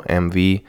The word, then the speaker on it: MV: Wie ich gerade eben schon gesagt habe, mein eigentlicher Name ist Mariano MV. Das äh, sind meine Initialen MV, englisch ausgesprochen.